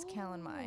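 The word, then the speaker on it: Calanmai: I'm still very unsure if I'm saying that correctly, but I'm gonna go with that's how all the audiobooks say it. Is Callan Mai. – Oh, thank God. I don't think I've made it that far. – Yes, Callan Mai. It's Callan Mai.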